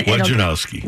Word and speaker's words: Wojnarowski: I'm sure Woj gets his name butchered on a daily basis, but just think four syllables and then Wojnarowski.